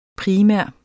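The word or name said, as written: primær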